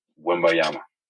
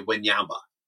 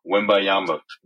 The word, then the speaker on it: Wembanyama: How do you say his name? – Wembanyama. – Yeah, I cut off a syllable and I say Wanyama. – Yeah, you missed the other one.